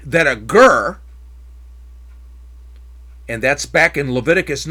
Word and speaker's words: ger: The Hebrew word there is ger, G-E-R. That's the transliteration. That's how you pronounce it.